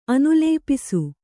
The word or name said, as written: ಅನುಲೇಪಿಸು